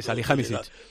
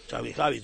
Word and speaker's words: Salihamidžić: Y este señor está, él y sus discípulos, Khan, el de nombre este serbio, Samilisevich o así. – Sí, Salihamidžić. – Salihamidžić, todo eso, porque yo a estas pronunciaciones no llego.